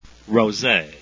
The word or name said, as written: rose